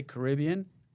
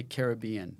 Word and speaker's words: Caribbean: But some people pronounce that weird. Some people say Caribbean, and some people say Caribbean.